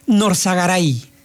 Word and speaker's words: Norzagaray: Se apellida algo así como Nonsegaray. Ahorita te lo digo bien, aquí está. Se llama David Norzagaray. David Norzagaray.